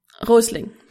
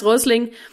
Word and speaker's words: Rösling: This quote is by beloved Hans Rösling, or did I pronounce that right? – Rösling. – Hans Rösling.